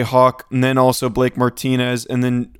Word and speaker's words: Martinez: I have confirmed it is pronounced Blake Martinez. Found a video of Peter Schrager saying Martinez, but he was used to a productive extent in Green Bay.